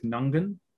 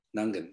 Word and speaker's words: Nungan: But I'm going to roll with Nungan. – Nungan. – I'm going to say there's the first G, so NG, that G is silent. I think it's Nungan. – Nungan.